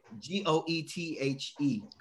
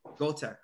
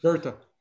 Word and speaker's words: Goethe: Now there was a there's a saying of I think his name was uh, uh I always pronounce it wrong. It's Goche Goche. It's uh like G O E T H E Goethe. Göta, right? Goethe. Say the, it. The E is almost like an R.